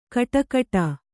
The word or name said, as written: ಗುಡಿಗುಡಿ